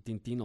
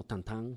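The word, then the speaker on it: Tintin: Eh, Tintín o Tantán, como, como, dicen que se debe pronunciar, en, francés.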